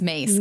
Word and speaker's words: Myss: It's Mace, Carolyn Mace, but it's spelled M-Y-S-S.